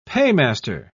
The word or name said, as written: paymaster